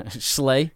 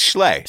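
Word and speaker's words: Schlee: Slay? – Yeah. Yeah, it's spelled S-H... S-C-H-L-E-E. – Yeah. – So it is, it's Schley.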